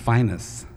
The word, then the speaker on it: Phineas: You knew him as Phineas Newborn, but down in Memphis, the word Phineas, which is spelled P-H-I-N-E-A-S, is pronounced Finus, and we call this Finus Can Be.